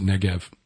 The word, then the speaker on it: Negev: It's spelled Negeb, properly. But we pronounce it Negev.